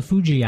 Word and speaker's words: Fuji: Now let me ask you about the Fuji apples, if I pronounced that correctly.